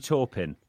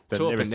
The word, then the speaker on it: Taupin: He plays Bernie Taplin. Is that how you pronounce it? – Bernie Taupin. – Taupin, there we go, sorry.